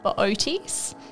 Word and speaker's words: Bootes: I'd probably steer you towards the constellation called Bootes. – Bootes? – Bootes, yeah. So it's, how is that spelt? – Oh, it's B-O-O-T-E-S, but you pronounce both O's, which is an odd one to say, definitely.